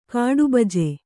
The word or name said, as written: ಕಾಡುಬಜೆ